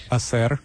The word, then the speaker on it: hacer: Hacker is a word derived from Spanish, H-A-C-E-R. How would you pronounce that? – Hacer.